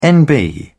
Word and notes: nb: British English

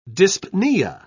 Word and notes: dyspnoea: British English

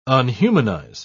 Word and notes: unhumanize: British English

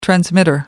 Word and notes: transmitter: American English

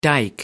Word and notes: dike: American English

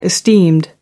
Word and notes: esteemed: British English

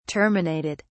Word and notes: terminated: British English

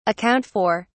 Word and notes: account for: British English

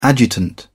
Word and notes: adjutant: British English

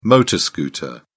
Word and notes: motor scooter: British English

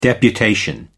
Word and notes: deputation: American English

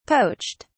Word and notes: poached: British English

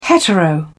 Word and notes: hetero: British English

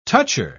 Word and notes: toucher: British English

